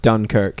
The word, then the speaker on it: Dunkirk: Dunkirk. – For sure. – How do we say it? – It's pronounced Dunkirk. – Dunkirk.